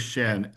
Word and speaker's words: Schen: My last name is Shen. S-C-H-E-N.